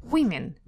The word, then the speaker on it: women: Она написала, я была очень удивлена, что множественное число женщин произносится как women. Women. Именно так.